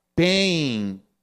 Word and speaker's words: Paine: El hombre se llama Thomas Paine, P-A-I-N-E. Por si acaso pensó que dije dolor. Eh, ¿Cómo se dice? Porque dolor es pain, ¿right? Y, y, pero él es pain.